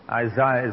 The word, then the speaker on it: Isaiah: And if you have your Bible, I'm going to read some verses from Isaiah chapter 49, or Isaiah, I think you people say. Is that right? Isaiah is the correct way to say it.